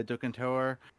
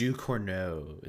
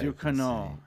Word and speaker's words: Ducournau: It's the new horror film by Julia Ducournau. I definitely said her name wrong. How do you say her name, Julia? – I think it's Ducournau. Is how I. – Ducournau. Yeah.